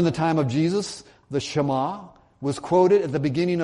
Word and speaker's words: Shema: It's spelled S-H-E-M-A, but it's pronounced Shema.